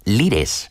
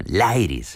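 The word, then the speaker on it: Lyres: En esa entrevista nos contó que le gustaba eso de que en España los llamásemos los Lires, que es como se escribe el nombre de la banda, Lairis en inglés, sería liras, la traducción, como el instrumento de cuerda, las liras.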